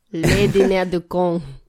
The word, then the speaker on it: Le Dîner de Cons: You don't pronounce the S. Le Dîner de Cons.